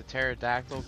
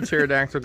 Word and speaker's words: pterodactyl: It's like, why can't you hear the pterodactyl go to the bathroom? Why don't you hear a pterodactyl go to the bathroom? Because the P is silent.